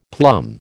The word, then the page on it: plum: American English